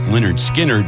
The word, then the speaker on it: Lynyrd Skynyrd: It's Joe Rock, and it was today in 1973 that the band released their debut album, pronounced Lynyrd Skynyrd.